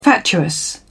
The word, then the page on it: fatuous: British English